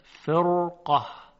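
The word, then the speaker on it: فرقة: در اینجا هم حرف را ساکنه، قبلش کسره هست، اما بعد از حرف را حرف قاف قرار گرفته که استعلا داره و درشت تلفظ میشه. به همین مناسبت را هم تفخیم میشود، فرقه.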